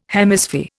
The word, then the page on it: Hemisphere: Indian